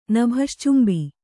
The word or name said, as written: ನಭಶ್ಚುಂಬಿ